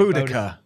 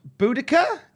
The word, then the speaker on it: Boudica: Boudica? Is that Boudica? – Yeah, I think it's alluding to the same person. – Okay, Boudica. – It's Boudica, Boadicea. There's like loads of ways to pronounce it.